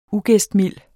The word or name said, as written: ugæstmild